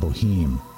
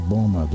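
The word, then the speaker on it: Böhme: This is from the German mystic and cobbler Jacob Bohme. Some might pronounce his name Jacob Bohem. Jacob Bohme, the German mystic.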